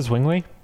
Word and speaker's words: Zwingli: Is that the Zwingli? – Yes, that is how you pronounce it. – Learn your theologians, Chris, come on.